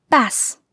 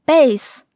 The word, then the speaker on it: bass: 比如由字母 bass 组成的单词，当中间的元音读作短元音 a 的时候，单词就是 bass，意思是鲈鱼。但当中间的元音变成双元音 a 的时候，就读作 bass，意思是低音、低音的，或者贝斯、低音吉他。